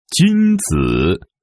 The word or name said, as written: Junzi